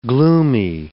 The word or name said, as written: gloomy